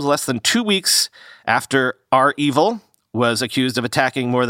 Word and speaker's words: REvil: Remember, REvil that big ransomware gang, by the way, I've learned it's pronounced R evil, not Revel, as I was saying.